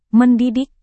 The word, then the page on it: mendidik: Indonesian